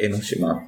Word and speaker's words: 江島: これ結構いろんな人に言われるんですけど、まずこの江島と書いて江ノ島って読むんですね。